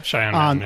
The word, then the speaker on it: Cheyenne: I also got it wrong because I saw Cheyenne or whatever it's pronounced. – Yeah, Cheyenne.